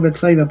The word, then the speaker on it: Bethsaida: Y dice así, enseguida hizo a sus discípulos entrar en la barca e ir delante de él a Bethsaida o Bethsaida. No, yo creo Bethsaida porque no tiene acento en la otra ribera, entre tanto que él despedía a la multitud.